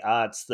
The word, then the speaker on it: aughts: But I feel like, late 90s or early aughts, like, did I say that right, Chad? You say it all the time, and I feel like I don't know how to say odds.